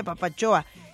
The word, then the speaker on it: papatzoa: Se escribe papatzoa, pero se pronuncia papachoa.